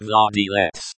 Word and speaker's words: владелец: The word owner in Russian is владелец, pronounced владелец.